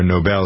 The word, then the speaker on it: Nobel: Each prize is worth 8 million kroner, which is $1.2 million, and it was established by Alfred Nobel, or Nobel, some people pronounce it, and if I remember correctly, he was the guy that invented dynamite.